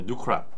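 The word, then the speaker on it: new crop: 뉴캡도요. 예, 뉴크랍. 아 뉴크랍도요. 예. 아 죄송합니다. 자꾸 뭐 저는 캡이라고 발음을 해서 음.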